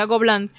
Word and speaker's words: Gobelins: se escribe Gobelins, pero se pronuncia Gobelins, una universidad de animación, y de ahí salen unos animadores tan hijo de puta, ¿sabes?